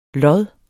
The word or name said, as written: lodden